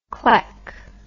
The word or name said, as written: cleck